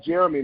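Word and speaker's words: Jheramie: you can find me by my email address, uh, Jeremy now.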